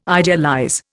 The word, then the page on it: idealize: Indian